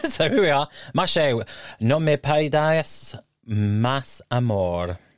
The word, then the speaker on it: No Me Pidas Más Amor: And this song is called No Me Pidas Más Amor. I think I've pronounced that correctly. – No Me Pidas Más Amor. – Oh, yeah. There you go.